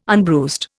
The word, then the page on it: unbruised: Indian